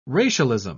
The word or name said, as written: racialism